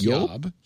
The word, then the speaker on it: Job: His name is spelled J-O-B, that's his first name, which I think maybe I like Yob. Yeah, I think that's what you said last time.